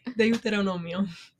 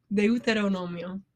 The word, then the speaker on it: Deuteronomio: How do you say it? – Deuteronomio. – Say it again. – Deuteronomio.